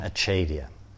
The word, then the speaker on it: acedia: So first at the top of the page there, sloth, also called apathy, also called in the tradition achedia, or acedia. What is the American pronunciation? Acedia, okay.